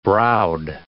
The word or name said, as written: browed